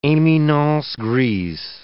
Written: eminences grises

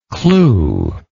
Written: clou

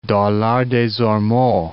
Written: Dollard-des-Ormeaux

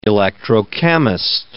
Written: electrochemist

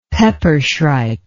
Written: peppershrike

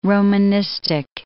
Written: Romanistic

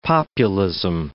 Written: populism

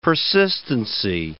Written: persistency

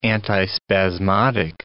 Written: antispasmodic

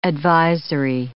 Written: advisory

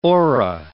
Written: ora

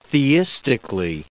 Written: theistically

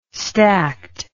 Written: stacked

